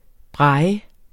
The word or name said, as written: braille